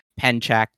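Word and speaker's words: Pencak: I should mention it's pronounced Penchak.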